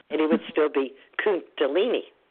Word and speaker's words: Kundalini: Kundalini could be spelled with a C, couldn't it? If it was spelled C U N D A L I N I, we'd pronounce it just the same. We'd say Kundalini, right? – Yeah.